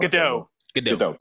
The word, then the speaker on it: Gadot: The new footage, which runs at 34 seconds, puts a spotlight on Wonder Woman, on Gal Gadot. If I said it wrong, Gadot. – Thank you, Gal Gadot.